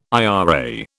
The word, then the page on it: IRA: British English